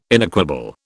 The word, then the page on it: inequable: British English